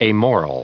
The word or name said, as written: amoral